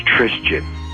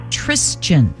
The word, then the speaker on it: Trisjin: It's Trisjan. T-R-I-S-J-I-N. I made it up myself. – Okay, and your older son? – Is Justin. – Justin, Joseph, and... – Trisjan. Yeah, T-R-I-S-J-I-N.